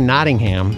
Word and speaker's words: Nottingham: So how do you say Nottingham? – I'd say Nottingham. – See, Nottingham.